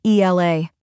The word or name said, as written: ELA